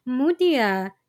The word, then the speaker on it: Meutia: Side note, I didn't really make the connection until I googled how to pronounce her name, because Mutia, spelled M-E-U-T-I-A, is pronounced much the same way as Mutya, M-U-T-Y-A, the Filipino word for pearl.